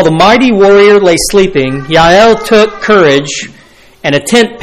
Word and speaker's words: Jael: Looks like Jael, but it's pronounced Yael.